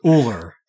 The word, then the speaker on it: Ullr: So, the normal pronunciation is Uller. The R is quick at the end, and there's a heavy accent on the U.